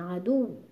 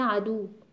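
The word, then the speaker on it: عدوّ: پس ببینید دیگه خیلی مهمه که حتما نبرش ادا بشه، عدو عدو. اگه ما این فشار رو نیاریم میشه عدو، انگار با واو مدی داریم حرف دال رو تلفظ میکنیم.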